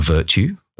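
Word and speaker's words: Virtu: So the product range is called Virtue. That's virtue without the E on the end.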